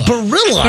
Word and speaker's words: Barilla: Barilla. No, it's Barilla. – Barilla. – How do you spell it? – B-A-R-I-L-L-A.